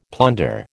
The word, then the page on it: plunder: American English